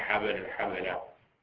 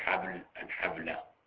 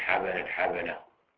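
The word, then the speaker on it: حَبَل الحَبَلة: الأولى قوله نهى عن بيع حبل الحبلة بفتح الباء فيه، وجاءت بإسكان الباء حبل الحبلة، وهو غلط ولحن لا يصح النطق به، لا يقال نهى عن بيع حبل الحبلة، بل لابد من التحريك، نهى عن بيع حبل الحبلة، تحريك الباء.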